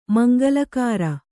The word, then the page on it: ಮಂಗಳಕರ: Kannada